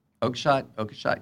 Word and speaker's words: Oakeshott: Oakeshott? – Oakeshott, yeah.